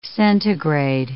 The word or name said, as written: centigrade